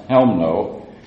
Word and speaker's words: Chełmno: It's spelled C-H-E-L-M-N-O, but in Polish the C is silent, so it's pronounced Chełmno.